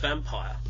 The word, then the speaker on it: Vampyr: Pronounced Vampire. – Vampire, yeah, that's all correct.